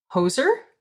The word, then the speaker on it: Hoxha: It's almost like it should be spelled H-O-X-H-A, the way the audiobook pronounces it, like hoser.